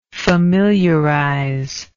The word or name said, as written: familiarizer